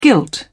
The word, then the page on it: gilt: British English